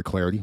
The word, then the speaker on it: Clardy: Follow me on Twitter at Troy Clarity. Last name is spelled C-L-A-R-D-Y.